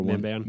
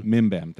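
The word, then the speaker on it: Mimban: What is it? Mambib? I always get that name wrong. Whatever. Yeah. Where he's in the sweet World War I. – Mimbam. – Mimbam. Thank you.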